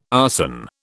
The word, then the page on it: arson: British English